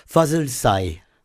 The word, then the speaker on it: Fazıl Say: Alors, je vous donne la prononciation turque, Fazıl Say.